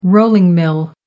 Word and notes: rolling mill: American English

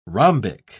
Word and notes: rhombic: British English

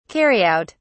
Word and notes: carry out: British English

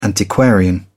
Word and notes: antiquarian: British English

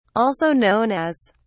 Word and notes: also known as: British English

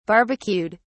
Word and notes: barbecued: British English

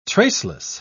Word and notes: traceless: British English

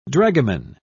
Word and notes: dragoman: British English

